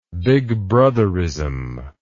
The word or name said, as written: Big Brotherism